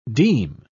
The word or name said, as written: deme